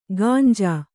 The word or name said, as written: ಗಾಂಜಾ